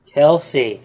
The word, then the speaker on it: Kelsey: And that is from Kelly Say, because you've spelled her name wrong. – Kelsey, jeez. – You put in an extra Y.